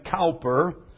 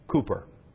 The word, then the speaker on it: Cowper: Some call him Cowper because his name is spelled C-O-W-P-E-R. But you must remember that the English call a cow a coo. So this is William Cooper.